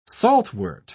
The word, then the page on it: saltwort: British English